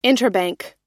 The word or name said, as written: interbank